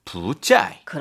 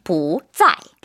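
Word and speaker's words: 부짜이 (不在): 사성 앞에서만 몇 성으로 발음할까요? – 좀 이렇게 올라가야 될 것 같아요. 부드럽게. – 부드럽게. – 부짜이. – 그렇죠. 네. 이성으로 부. – 부. – 부짜이.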